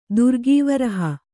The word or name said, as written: ದುರ್ಗೀವರಹ